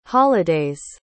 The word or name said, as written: holiday's